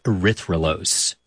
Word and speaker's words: erythrulose: And they scanned it for radio signals of different molecules, which is how they found a four-carbon sugar called erythrulose.